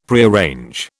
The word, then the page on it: Prearrange: British English